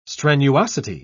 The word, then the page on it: strenuosity: British English